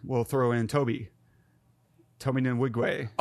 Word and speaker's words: Nwigwe: Tobe Nwigwe. Nwigwe um, he has this track called "I Choose You."